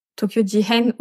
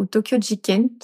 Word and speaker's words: Tokyo Jihen: Et, et aussi son groupe euh, qu'elle a créé, je crois, euh, vers 2015, qui s'appelle euh, Tokyo Ji Tokyo Jihen ou Tokyo Jiken, ça dépend de la prononciation, qui veut dire scandale à Tokyo.